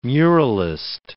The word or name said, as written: muralist